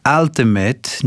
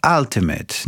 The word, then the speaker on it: ultimate: Redaktorze, z całym szacunkiem, ale słowo ultimate nie wymawia się ultimate, tylko ultimate.